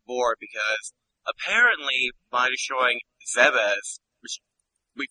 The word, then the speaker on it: Zebes: Zebes has an official pronunciation. I still prefer Zebes.